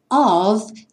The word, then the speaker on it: of: Of tem a pronúncia do F como se fosse um V. Of.